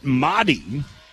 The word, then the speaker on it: Mari: You say it Mahdi, you spell it M-A-R-I.